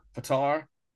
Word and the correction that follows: Pavitar: Pavitar. – Pavitar.